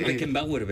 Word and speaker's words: Beckenbauer: Dice Beckenbauer, ben.